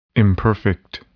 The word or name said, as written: imperfect